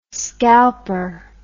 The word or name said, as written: scalper